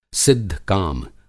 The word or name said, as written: सिद्ध-काम